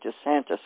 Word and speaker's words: DeSantis: You do pronounce it DeSantis, right? – Yeah, okay.